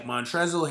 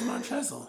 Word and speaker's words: Montrezl: Montrezl Harrell and Patrick Beverley. I thought it was Montrezl. The L is silent? – Hell yeah, the L is silent, bro.